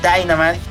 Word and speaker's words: Dynamite: Sí, lo pronuncié bien, Dynamite, porque